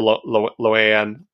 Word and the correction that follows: La'an: or I can never pronounce her name. – La'an, I think, is how they usually.